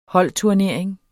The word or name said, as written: holdturnering